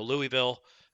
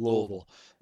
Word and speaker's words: Louisville: Or Louisville, however we're supposed to pronounce Louisville. – It. I forget. – Louisville. – Louisville.